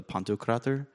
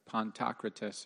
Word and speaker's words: Pantocrator: That's how you pronounce that? – Yeah. – There's words I've read and then I never heard anyone say them out loud, so I always was like Pantocrates or whatever.